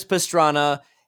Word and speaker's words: Pastrana: Yeah, pastrami. Sorry. – I was going to say. – That's just me being an American and mispronouncing his Italian last name. Travis Pastrana had just hit the double backflip on the dirt bike.